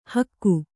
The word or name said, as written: ಹಿಕ್ಕೆ